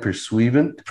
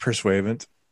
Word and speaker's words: Pursuivant: Isn't that pronounced the Grand Pursuivant? – Pursuivant. – Yeah.